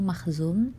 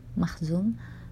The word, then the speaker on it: Makhzum: Di sini ejaannya yang aneh itu Mahzum M A K H Z U M Mahzum.